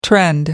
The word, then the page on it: trend: British English